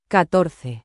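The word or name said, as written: catorce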